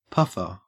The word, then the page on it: puffer: Australian English